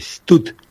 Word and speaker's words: stud: Es una palabra en inglés que se escribe stud, S-T-U-D, stud.